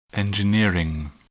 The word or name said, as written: engineering